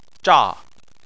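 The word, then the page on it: zha: tone 4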